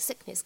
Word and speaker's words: sickness: 好，再来看一下下面这样一个单词 sickness，它当中的话呢，存在一个不完全爆破的现象，所以的话呢，你不需要读成 sickness。当中我们说这个的发音呢，只是做了一个口型，并没有完全发出来。